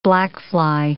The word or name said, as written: blackfly